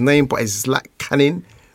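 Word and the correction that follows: Zlatičanin: who is the title holder. – Zlatikanin. – Yeah. Thank you, teacher.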